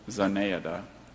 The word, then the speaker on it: Zenaida: Zenaida. – Zenaida? Are there any alternate pronunciations? – Just the one. Zanayada.